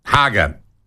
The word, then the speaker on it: Hager: Hager. Rhymes with lager.